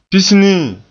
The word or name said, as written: pisnii